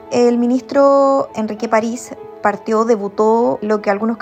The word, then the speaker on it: París: París como si tuviera acento en la I. Así lo dice el mismo ministro cuando le preguntan el cómo se pronuncia su apellido.